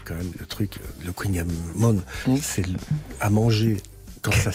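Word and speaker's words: kouign-amann: Si on dit Queen Yaman, c'est Yann Kefelec, donc quand même il fait référence. – Référence, oui. – Qui prononce Queen Yaman.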